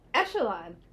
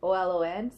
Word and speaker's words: echelon: How is it really spelled? – Echelon. – Oh. – But it's spelled Echolon, so people say Echelon. – Oh, ew. – Yeah. I can't even say it wrong. But it's Echelon.